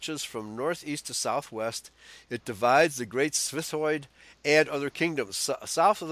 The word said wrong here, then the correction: Svithjod: That's Svithjad. – Svithjad, okay. – Yeah, the J is pronounced as a Y. – Right, okay. – Same as it is in the Hebrew.